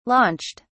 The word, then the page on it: launched: British English